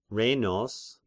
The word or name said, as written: Rhenos